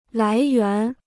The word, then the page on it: 来源: lái yuán